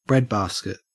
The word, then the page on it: breadbasket: Australian English